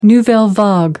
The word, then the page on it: nouvelle vague: American English